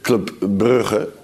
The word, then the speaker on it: Club Brugge: Bare for uh, å ha Vi har diskutert, vi diskutert Klubb Brygge i dag, ja. men vi har jo alle gjort det uten å, å, uttale det riktig. Vil vi ha riktig uttale fra Louis van Gaal?